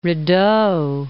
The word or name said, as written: Rideau Canal